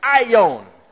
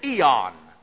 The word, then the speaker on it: aeon: Then the third Greek word that is translated world is aeon. We've taken it in English and pronounce it eon. Aeon.